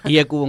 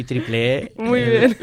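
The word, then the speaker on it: IEEE: IECUBO en castellano y triple E latinoamericano.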